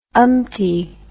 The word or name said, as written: umpty